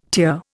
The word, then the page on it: tier: Indian